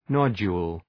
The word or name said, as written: nodule